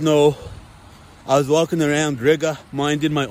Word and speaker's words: Riga: You guys remember that Latvian chick I met a couple of weeks ago in Riga, which rhymes with nigga?